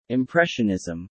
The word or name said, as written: Impressionism